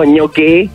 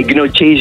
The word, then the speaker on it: gnocchi: No ještě jako ňoky, kde máte jako gnocchi, tak to všichni říkají gnoči, že jo, a takhle, tak to je určitě jeden z hlavních kluserů.